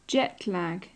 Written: jet-lag

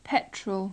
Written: petrol

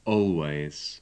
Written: always

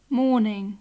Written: morning

